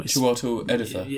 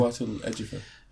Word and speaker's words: Chiwetel Ejiofor: Chiwetel Ejiofor. – It's a tricky name. It's got a J in it for some reason. – Chiwetel Ejiofor.